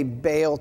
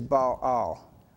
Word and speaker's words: Baal: And so when we say Baal today, that's where the pronunciation comes from. But the most correct way to say this is Baal. Two different syllables.